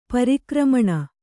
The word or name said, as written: ಪರಾಕ್ರಮಣ